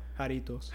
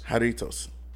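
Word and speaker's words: Jarritos: Jaritos. – Jaritos.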